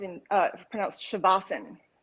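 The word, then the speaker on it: Shavasana (शवासन): The A is actually silent, so Shavasana is actually pronounced Shavasana.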